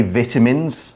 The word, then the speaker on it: vitamins: Now normally I would say vitamins, mm-hmm. – uh, mm. that is the British pronunciation. Yes. I'm using the American pronunciation cuz somehow is... I think it's actually right. Really? – Because vita comes from vital.